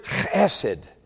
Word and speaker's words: chesed: That word mercy in the Old Testament, the Hebrew word is chesed. If you say it right, you got to clear your throat, like if you ever say Bach right in German, you know.